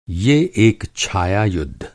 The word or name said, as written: छाया